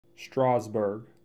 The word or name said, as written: Strasburg